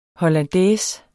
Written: HOLLANDAISE

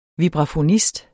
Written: vibrafonist

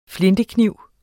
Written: flintekniv

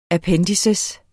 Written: appendiks

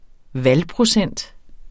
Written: valgprocent

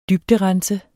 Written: dybderense

